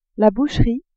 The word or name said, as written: boucherie